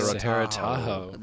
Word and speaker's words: Sahara Tahoe: Sahara Tahoe.